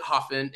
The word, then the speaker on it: Hafen: Okay, well, first of all, it's a German word, and they pronounce it Hafen, and it means harbor.